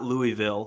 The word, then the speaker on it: Louisville: It's pronounced L-U-A-V-U-L, Louisville.